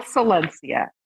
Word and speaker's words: Solensia: Called, It's called Solensia, S-O-L-E-N-S-I-A.